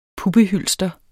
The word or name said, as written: puppehylster